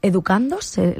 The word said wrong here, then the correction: educando: Sí, educando, sí, sí.